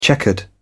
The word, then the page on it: checkered: British English